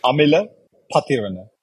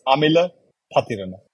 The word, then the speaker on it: Amila Pathirana: It's Amila Patharana. Let me repeat that again. It's Amila Patharana.